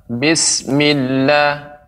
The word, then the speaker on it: بسم الله: أعوذ بالله من الشيطان الرجيم بسم الله الرحمن الرحيم Ya, begitu juga pengucapan Bismillah. Ini ada seperti ditekan Milla, Bismillah. Tidak terlalu cepat. Bismillah, Nah, ini uh, terlalu sedikit eh, apa namanya tasdidnya. Lebih ditambah lagi bismillah millah.